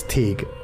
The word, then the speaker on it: Teague: But Amanda is her first name, Large, L-A-R-G-E, her okay. middle name. And a surname, T-E-A-G-U-E, which is pronounced as Tig.